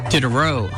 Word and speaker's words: Diderot: Dennis Diderot is the pronunciation.